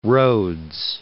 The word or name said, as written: Rhodes